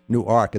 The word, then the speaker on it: Newark: Funny, I was just in Newark a couple of weeks ago, and so I know it's not pronounced Newark. It says Newark, but it's Newark. And then there's something also L-E-W-E-S, which we want to say Lewis, but it's Lewis.